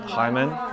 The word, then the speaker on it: Hymen: Not religiously wise, but the Haman, is that how you pronounce it? – Hymen, yeah.